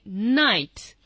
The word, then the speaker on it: knight: You don't pronounce the k.I have no idea why, to be honest, but you say night.